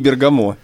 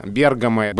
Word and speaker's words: Бергамо: никак не Бергамо. – Да, Бергамо ближе к итальянскому произношению. Бергамо вошло уже в русскую культуру с таким ударением. Труффальдино из Бергамо, и мы, конечно, это помним, и нам сложно говорить Бергамо.